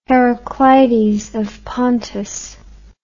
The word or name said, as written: Heracleides of Pontus